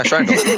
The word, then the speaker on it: Schreindel: Like, what happened was I was giving a talk, and the person who was directing the event, they could not say my last name, which is Schreindel. And that's how that's pronounced. – Yeah, Schreindel.